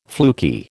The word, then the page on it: fluky: American English